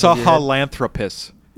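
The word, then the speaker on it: Sahelanthropus: Sahalanthropus.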